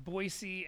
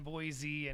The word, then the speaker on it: Boise: You got Boise and Boise, and it's always a good laugh to me. – I always say Boise. Boise. I can't even say it right. Boise. – I know. You can say it either way.